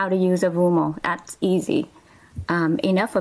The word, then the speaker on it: Avumo: Avumo is spelled A V U M O.